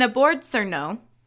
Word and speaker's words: obwarzanek: the beginnings of the modern bagel can be traced to the Polish and a board surno. That's how you say that. Are you serious? Yep. Yep. No way. Yep. It is spelled O B W A R Z A N E K.